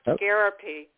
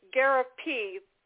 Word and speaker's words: Gariepy: Hey, Ryan, it's pronounced Gariepy. – Gariepy? Why did I have it with a P? I must have misspelled it No, you spelled it right. It's Gariepy, G-A-R-I-E, P as in Paul, Y. – Okay, let's see. All right, because I got G-A-P-R-I-R-I-E-Y.